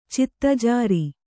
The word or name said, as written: ಚಿತ್ತಜಾರಿ